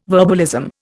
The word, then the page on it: verbalism: Indian